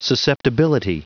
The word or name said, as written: susceptibility